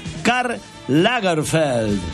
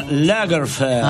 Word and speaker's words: Karl Lagerfeld: 1933 nace el reconocido diseñador de moda alemán Karl Lagerfeld. Le mandamos un beso. – Que pronuncia. – El de pelo blanco, ese que tiene siempre un pañuelo. – Claro, Karl Lagerfeld.